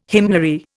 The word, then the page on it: hymnary: Indian